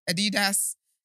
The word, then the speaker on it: Adidas: He also was fined £250,000 and he lost a substantial sponsorship with Adidas. Yes, that's how you say it.